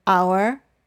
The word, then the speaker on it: hour: But it is silent in the words honesty, hour, and honor.